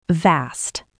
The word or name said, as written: vast